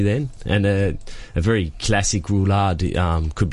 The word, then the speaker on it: roulade: Roulade. – Roulade.